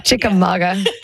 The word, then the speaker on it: Chickamauga: Did I say that right? – Chickamauga. – Chickamauga. – Yeah, it's a Cherokee word.